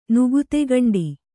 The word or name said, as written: ನುಗುತೆಗಂಡಿ